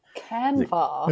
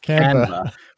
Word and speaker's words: Canva: How do you pronounce the company's name? – Canva. – It's Canva.